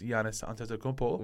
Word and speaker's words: Giannis Antetokounmpo: Right, we'll just say that my number two is Giannis Antetokounmpo. – Wow. – I got it right. – Number two. Yeah, you got the pronunciation right.